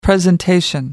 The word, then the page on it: presentation: American English